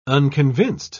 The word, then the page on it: unconvinced: British English